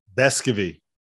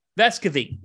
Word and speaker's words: Vescovi: He's changed his own pronunciation like four times. Yeah. – I know. Yeah, yeah. I, yeah, I, I know so he like has. Vescovy, Vescovy. Depends on what mood he's in.